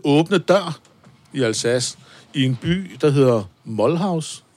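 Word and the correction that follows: Mulhouse: i Alsace. Eller det er jo Frankrig, Molhaus. Altså, Moulouse. – Moulouse. Moulouse.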